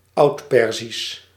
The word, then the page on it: Oudperzisch: Oud·per·zisch